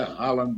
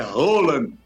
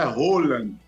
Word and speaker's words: Haaland: Aliás, a palavra, o nome não é Haaland, não. É Roland, viu, Tom Barros? A pronúncia é Roland.